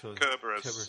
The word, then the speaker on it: Kerberos: We technically butchered all those words too, because I think with Greek they're supposed to be hard C's, so it should be the Centaur and the Kerberos and Kerberos.